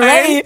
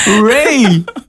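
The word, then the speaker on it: Raye: Reihe, so spricht man sie aus, ne? – Scheiße, ist mir noch nicht mal aufgefallen. – Nein. – Ray, nein. – Du hast stumm vorgelesen. Ray.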